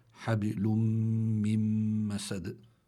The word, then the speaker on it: من مسد: مسد في جيدها حبل من مسد والله تعالى أعلم. – يعني الشيخ بس لنبين لمستمعينا الكرام يعني النون هون من مسد لا تلفظ